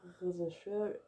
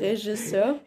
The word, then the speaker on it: Regisseur: wo der Regisseur. Regisseur. – Regisseur. ja, meine Aussprache.